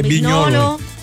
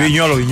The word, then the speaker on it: Vignolo: Se escribe Vignolo, pero Sebastián es Vignolo. – Miguel Simón. – Vignolo. – Mariano Clos y Fernando Palomo. – Bacano. – Es Vignolo.